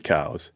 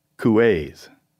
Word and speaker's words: Cowles: And in France, it was two syllables, couets.